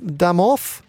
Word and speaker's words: домов: И, и вот, вот эти, эти цифры, да, вот один дом, два дома, Пядом домов? – Домов. Еще ударение же другое.